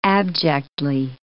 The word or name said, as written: abjectly